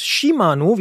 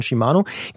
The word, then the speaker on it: Shimano: Shimano, wie wir gelernt haben, ist die richtige Aussprache von Shimano, gesteht ein, dass einige Rennradkurbeln aus den letzten Jahren brechen können und zwar wirklich viele.